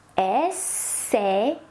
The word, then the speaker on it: essayer: AI is E. Essayer, essayer.